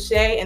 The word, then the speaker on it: Shay: Yeah, you can call me Shay Too Legit, you know, whatever. formerly Shaylin is my name. You can find me on YouTube at 50 Shades of Shay, and that's spelled the number 50, S-H-A-Y-D-E-S, of O-F-S-H-A-Y.